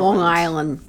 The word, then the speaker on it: Long Island: Long Island. – You gotta say it like it's one word.